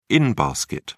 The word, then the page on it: in-basket: British English